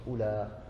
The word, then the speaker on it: أولى: وأما الذين يقرؤون بالقصر أولى فهم تميم قيس ربيعة أسد همدان لا تنطق أولى بمد إنما تنطق بالقصر أولى وأهل الحجاز أولئي ها؟